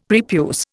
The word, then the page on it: prepuce: Indian